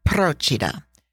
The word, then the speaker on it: Procida: Let's start with Procida. That is spelled P-R-O-C-I-D-A, and the emphasis is on the first syllable, just like Capri.